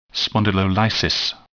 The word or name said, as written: spondylolysis